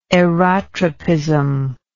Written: aerotropism

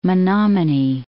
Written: Menominee

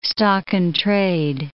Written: stock-in-trade

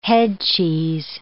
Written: headcheese

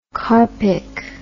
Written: carpic

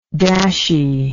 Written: dashy

